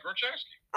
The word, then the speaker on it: Grochowski: Say your last name again, because it's called Grochowski. – Grochowski. Oh, I said it. No. I was saying it right then. Grochowski. – Yeah, Grochowski.